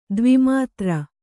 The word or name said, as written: ದ್ವಿಮಾತ್ರ